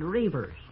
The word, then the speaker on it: Reavers: F-R-E-D, Fred. Reavers is spelled just like it sounds. It rhymes with beavers, but it's spelled differently, naturally. Lots of people have trouble with it.